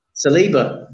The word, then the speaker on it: Saliba: How is it pronounced? Is it Saliba? Saliba? – Saliba.